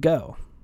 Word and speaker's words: Geaux: granger taylor was born on october 7th 1948 oh my sources for this one uh, there is a youtube channel called go it's spelled g-e-a-u-x kind of a smaller channel she did a very good rundown of uh, Granger's experience.